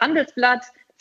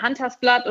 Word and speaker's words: Handelsblatt: Ich kann mich erinnern, viele Leute haben das Wort Handelsblatt falsch ausgesprochen. Es war dann Huntersblatt oder Hanselblatt.